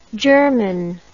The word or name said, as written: germon